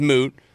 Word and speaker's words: Moote: Like, my name's Moot. I get to pronounce Moat, Mot. Like Moti, Mooti. – Because it's spelled M-O-O-T-E.